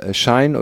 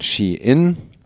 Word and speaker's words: Shein: Schein oder Nichtschein, das ist natürlich nicht korrekt ausgesprochen, weil der Konzern, über den es geht, um den es geht, wird eher ausgesprochen Shein.